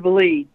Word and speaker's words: Vliet: It's Dr. Vliet, James, and John, glad to be with you. – Likewise. – Vliet, it rhymes with fleet.